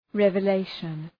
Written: revelation

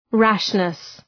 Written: rashness

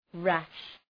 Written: rash

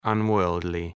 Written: unworldly

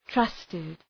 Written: trusted

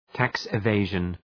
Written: tax evasion

